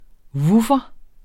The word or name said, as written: vuffer